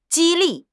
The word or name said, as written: 激励